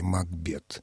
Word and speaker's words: Макбет: Действительно, если воспринимать его фамилию как шотландскую, вот с этой характерной приставкой «мак», то тогда ударение должно быть на втором слоге «Макбет».